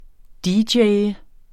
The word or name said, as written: dj